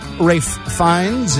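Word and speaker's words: Ralph Fiennes: Rafe Fiennes is 64.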